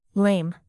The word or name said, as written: lame